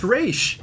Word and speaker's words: Ra's: In that language, it's pronounced Raish.